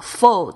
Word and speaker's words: full: 我们用这个 full 这个字，f u l l，大家特别注意这个发音哦。这个 full 啊，full 啊，我有听过这个澳洲人，他这个 u 的音呢，他会发的比较扁平，所以他会听起来像是 full full 哦。